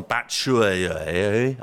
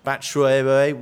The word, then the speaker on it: Batshuayi: Sends on Pedro, sends on Moses, sends on Batshuayi, I believe is the way it is pronounced, Rog. We're waiting for the pronunciation guide from NBC for that, but I believe it will be Batshuayi.